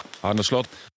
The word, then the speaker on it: Arne Slot: Arne Slot. – Det siste der for øvrig hvordan navnet hans uttales på nederlandsk, så la oss høre det en gang til. – Arne Slot.